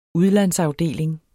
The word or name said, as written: udlandsafdeling